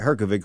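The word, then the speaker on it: Hrgović: If he doesn't take this fight, Flip Hrgonik is going to step in instead. He's supposed Herkovic. – To take how do you say that? – Herkovic. – All right, Herkovic is going to step in.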